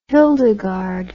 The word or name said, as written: hildegard